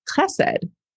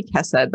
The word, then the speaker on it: Chesed: A lovely, thoughtful comment from someone named, I hope I'm pronouncing this correctly, Chesed. So shout out to you, Chesed. It might be Chesed, but it has the KH.